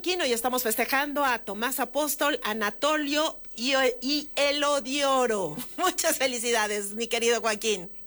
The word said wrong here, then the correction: Eliodoro: Eliodoro. – Eliodoro, sí lo dije mal.